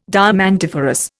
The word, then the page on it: diamantiferous: Indian